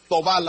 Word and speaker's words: Thauvin: Bueno, ya nos has clarificado la pronunciación correcta del nombre Thauvin. Yo pensaba que era John Rafa como Chopin, Thauvin, ¿no? En este caso, pero es Thauvin la pronunciación de este nuevo futbolista de los Tigres,